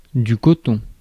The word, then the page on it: cotton: [ˈkɒt.ən]; American English